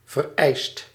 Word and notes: notwendig: /ˈnoːtvɛndɪç/

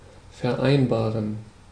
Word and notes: convenir: /kɔ̃v.niʁ/

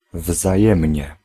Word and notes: pareil: France; [pa.ʁɛj]

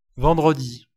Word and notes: vendredi: [vɑ̃.dʁə.ˈdi]; France

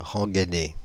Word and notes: rengainer: /ʁɑ̃.ɡɛ.ne/

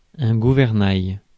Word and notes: gouvernail: France; /ɡu.vɛʁ.naj/

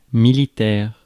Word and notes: militaire: France; [mi.li.tɛʁ]

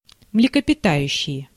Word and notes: млекопитающее: [mlʲɪkəpʲɪˈtajʉɕːɪjə]; mlekopitajuštšeje

